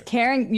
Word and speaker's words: Karen: It's Karen. You're adding a syllable.